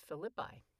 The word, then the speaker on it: Philippi: And actually, I'm pronouncing it because that's the way the Romans pronounced it. But in Shakespeare's scansion, if you do it, it's Philippi.